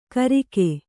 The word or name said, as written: ಕರಿಕೆ